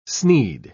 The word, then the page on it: snead: British English